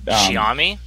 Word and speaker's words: Xiaomi: Think of Xiaomi, pronounce the first word as if it were shower. Show what? The mystery ends here. According to the Xiaomi? – Xiaomi, that's right. Xiao. Technically, the first syllable is more of a Xiao than a show, but the difference is fairly subtle to most American Indians. So it's Xiaomi, that's right.